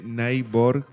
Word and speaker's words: Naiborg: Se escribe Naibor, así sí, como con suena. I latina, N-A-I-B-O-R-G.